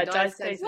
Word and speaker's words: diastasis: Um, you've talked to a fair bit about, and I'm probably not going to say this right, Diatesis recti. Is that yes? Have yeah, I said diastasis. that right? Oh, okay. Yeah, diastasis.